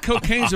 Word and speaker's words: Cokagne: Yeah, I thought cocaine's a pretty good weight loss program as it is. – It's spelled C-O-K-A-G-N-E, but it would be pronounced cocaine, right? – Hmm.